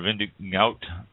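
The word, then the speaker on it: vin de goutte: They have their own word, Vindigo, I believe it's pronounced, G-O-U-T-T-E. Oh, there we're going to get somebody writing this, Mike. I said I pronounced it wrong. Vindigout.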